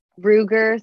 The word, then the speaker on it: Ruger: Shortly before 7 a.m., Jacob retrieved a, is it Ruger? Is that how you pronounce the gun? I don't know. A, like, Ruger. – Ruger 38 handgun.